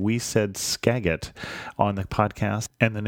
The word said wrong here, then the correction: Skagit: name of that river and county in Washington is Skagit. Even though it's spelled with a G, it's pronounced with a soft G.